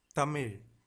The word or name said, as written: thamizh